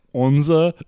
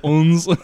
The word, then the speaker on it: Odense: And they're like, okay, so, like, the capital of Denmark is spelled something like O-D-E-N-S-E. And the German host of the show, she's like, so, you know, of course, in German, we would say Odense. – Yeah. – You know, and how would you say that in Danish? And the girl's like, it's kind of like onza or like uns.